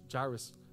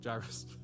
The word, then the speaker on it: Jairus: Not only that, but before this, when J- Jairus, yeah, I said it right. Jairus. – we want to say Jairus.